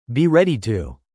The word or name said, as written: be ready to